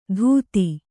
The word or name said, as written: ಧೂತಿ